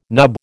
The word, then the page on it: nub: American English